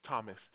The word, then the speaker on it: Thomas: Thomas, T-H-O-M-A-S.